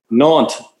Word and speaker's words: Nantes: Nantes. – Yeah, I believe he's right. – Nantes, omelette du fromage. – You don't usually pronounce the last letter in French. – No, especially if it's an S.